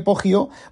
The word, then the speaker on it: Poggio: Comienza con una escena del Poggio este, Poggio, bueno, se escribe P-O-G-G-I-O-B-R-A-C-C-I-O-L-I-N-I,